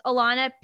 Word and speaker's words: Ilana: So that's spelled I-L-A-N-A. Pinsky is P-I-N-S-K-Y. My YouTube channel is just simply my name, Alana Pinsky. I'm on TikTok as Alana Pinsky, but there are two Ys. So it's Alana, I-L-A-N-A-P-I-N-S-K-Y-Y.